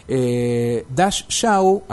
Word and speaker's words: Dash Shaw: Dash Shaw, así como se pronuncia, con SH, DASH, SHAW, Dash Shaw, es el autor, y el nombre de la obra en la edición de Editorial Común es Ombligo sin Fondo.